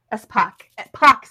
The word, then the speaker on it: Espach: I think is how you say her name. Espach. I think it's Espach. – Espach sounds right.